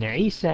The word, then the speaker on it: عيسى: And Isa and Musa both have Alif Maqsura at the end, so you don't pronounce the E sound.